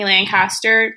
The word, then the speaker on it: Lancaster: You can follow me on Instagram, TikTok, and YouTube, all at Brittani Lancaster.